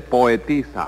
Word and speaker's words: poetisa: Y en este caso, este individuo lo que viene a ser es un poetizo. – No se dice poetizo, Tres Patines, se dice poetisa. – Ah, sí, acá es poetisa.